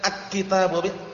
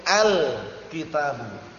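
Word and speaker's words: al kitabu (الكتاب): Ini kalau kemasukan al, jangan bacanya dengan akitabu, al kitabu.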